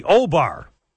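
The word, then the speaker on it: Au Bar: The uh, William Kennedy Smith case, it was the O Bar. It was spelled AU, but it's pronounced the O Bar.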